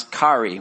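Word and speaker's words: kari (χαῖρε): The regular Greek greeting would have been probably how we pronounce kari, which is like chair with an E at the end of it.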